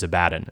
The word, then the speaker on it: Abaddon: His name in Hebrew is Abaddon.